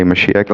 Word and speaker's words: Mashiach: And so Mashiach, Messiah, is how it's transliterated, but it's pronounced today Mashiach.